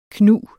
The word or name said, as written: knug